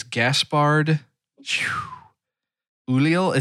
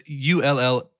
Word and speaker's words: Gaspard Ulliel: It is Gaspard Ulliel. It's Ulliel. And I should have looked up on how to pronounce that.